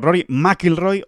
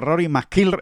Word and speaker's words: McIlroy: Con el acento en Mac, o sea, diciendo Rory McIlroy o Rory McIlroy, es que da igual, da igual como uno lo diga, que, siempre queda bien, ¿verdad?